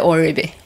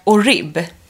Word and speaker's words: Oribe: Och visst är det så man uttalar det, Orbi. – Ja, jag säger Orbi. – Orbi. Ja, det är bra. – Ja. – Jag har ju nämligen tidigare sagt Orib. – Ja, och jag sa Oribe.